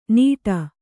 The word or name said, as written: ನೀಟ